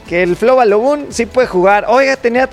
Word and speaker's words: Flo Balogun: Con el tema de Flo Balogun.